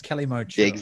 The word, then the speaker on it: Kalimotxo: I'm saying it wrong, aren't I? It's Kalimotxo.